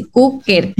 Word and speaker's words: Kuker: De hecho, es K-U-K-E-R, así, Cooker.